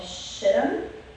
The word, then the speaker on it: Shittim: So they had been camped over here in this area to the right called Shittim. I looked up pronunciation, that's how it's said.